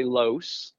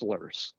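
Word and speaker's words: Loess: So some people say, if you're not from around here, you'd say Los or Lois. – Yeah. – But around here, it's pronounced Lurs.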